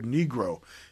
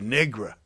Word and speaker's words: Negro: In fact, when Johnson was running for president in 64, they had to teach him how to pronounce the word Negro. He used to say Negra, and they knew that that would rub people the wrong way.